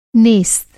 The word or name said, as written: नेस्त